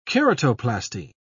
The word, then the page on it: keratoplasty: British English